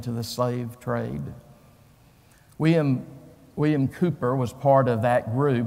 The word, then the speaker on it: Cowper: Now, Cowper is spelled C-O-W-P-E-R. But if you say Cowper in England, they'll laugh at you. It's William Cowper.